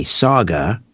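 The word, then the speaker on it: Sauga: Saga, as in Mississauga, is spelled S-A-U-G-A. S-A-U-G-A. S-A-U-G-A.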